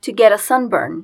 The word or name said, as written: to get a sunburn